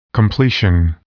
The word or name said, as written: completion